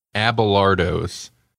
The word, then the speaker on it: Abelardo's: It's spelled Abelardo's. – Abelardo's. Okay. I knew it. Well, my pronunciation was closer, I think, than to what it actually is, but my Abelardo's.